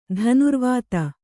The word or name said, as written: ಧನುರ್ವೇದಿ